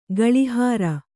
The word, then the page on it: ಗೂಳಿಹೋರಿ: gūḷi hōri